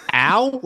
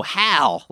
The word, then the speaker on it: Hal: Al? Like Albert? – No, Hal. Like Halbert. – There's an H. – Hal. Not like Albert, but Halbert.